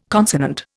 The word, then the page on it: consonant: Indian